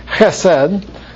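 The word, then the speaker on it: chesed (חסד): The H has got a ch on it, so you have to have a congestion in your throat or something to say it properly. Something like chesed or chesed.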